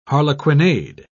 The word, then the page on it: harlequinade: British English